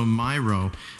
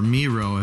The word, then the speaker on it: Miro: Or it has iTunes and also Miro, or Miro if you pronounce it that way, M-I-R-O.